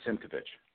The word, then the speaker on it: Simkovich: And how did I say your last name? – Perfectly fine. Simkovich.